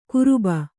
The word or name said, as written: ಕುರುಬ